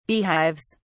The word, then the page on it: beehives: British English